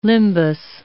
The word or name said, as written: limbus